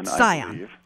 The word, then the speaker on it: Scion: And the answer to what the turning radius for this, is it Scion or how do I pronounce this, the IQEV? – Scion.